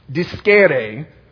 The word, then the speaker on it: discere: But Siri told me this is how it's pronounced. It comes from Latin. Discere is the word that disciple comes from.